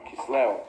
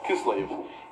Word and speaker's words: Kislev: called Kislev, or as Ashkenazim might pronounce it, Kislev.